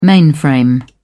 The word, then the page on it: mainframe: British English